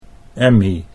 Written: Emmy

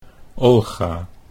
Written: Olga

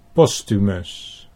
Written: Posthumus

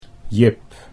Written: Jip

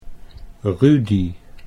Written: Rudy

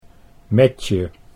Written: Metje

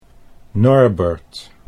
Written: Norbert

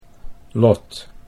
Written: Lot

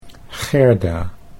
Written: Gerda

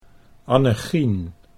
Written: Annechien

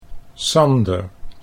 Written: Sander

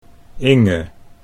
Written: Inge

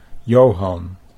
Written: Johan

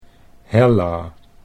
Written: Hella

